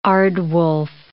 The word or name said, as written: aardwolf